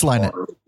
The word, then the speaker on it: Lynott: Phil Wynott. – It's Lynott. – Lynott? – Yeah.